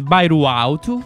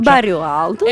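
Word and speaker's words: Bairro Alto: Allora, siamo nella zona del Bairuauto. Ti è piaciuta la pronuncia? – Sì. – Sono stato lì, ho imparato anche la pronuncia. – Bairuauto.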